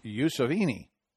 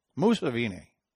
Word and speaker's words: Museveni: They were thanking their president, and I don't know if I'm saying this right, Yoweri Museveni. Sounds Italian, doesn't it? It's like Mussolini, but no, it's Museveni. Museveni.